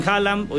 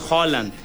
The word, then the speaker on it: Haaland: Mucha gente dice Erling Haaland, obviamente lo mexicanizamos, pero cuando son dos As juntas se pronuncia como O, entonces es Haaland.